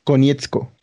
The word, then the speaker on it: Konietzko: Brian Konietzko.